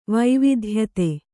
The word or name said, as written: ವೈವಿಧ್ಯತೆ